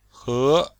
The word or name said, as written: he2